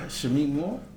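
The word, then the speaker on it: Shameik Moore: Shameek Moore? – That's the Shameek Moore, fair enough.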